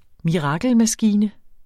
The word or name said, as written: mirakelmaskine